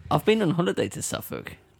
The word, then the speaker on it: Suffolk: How do Americans pronounce it? – Suffolk. – I've heard like Suffolk County. – Suffolk County. It's just Suffolk.